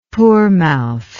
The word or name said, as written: poormouth